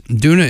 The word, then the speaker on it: Doona: They're called Duna Waves. Duna is spelled D-O-O-N-A.